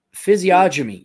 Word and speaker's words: physiognomy: I'm probably saying it physiognomy? Physiognomy. Yeah, you pronounce it right.